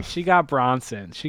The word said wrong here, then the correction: Brosnan: How do you say that? Brosnan. That's it.